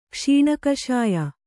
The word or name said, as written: ಕ್ಷೀಣಕಷಾಯ